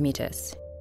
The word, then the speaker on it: Métis: Frank is Métis, which it should be noted he pronounces as Métis.